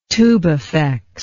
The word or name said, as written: tubifex